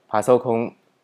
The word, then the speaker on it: パソコン: 那接下来最后这个单字呢，一样也使用平假名来做书写，它的意思是电脑的意思，它念作パソコン、パソコン。